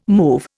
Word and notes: move: Indian

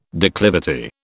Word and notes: declivity: British English

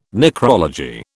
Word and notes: necrology: British English

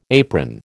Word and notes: apron: American English

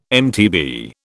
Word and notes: MTB: British English

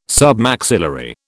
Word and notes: Submaxillary: British English

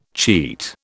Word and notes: cheat: British English

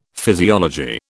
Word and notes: physiology: British English

